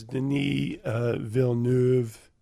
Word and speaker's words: Denis Villeneuve: The director is Denis Villeneuve.